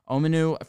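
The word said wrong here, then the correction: Omenihu: from the edge that I Omenahu. – thank you.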